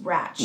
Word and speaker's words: Ratched: You don't pronounce the D, it's just Ratched.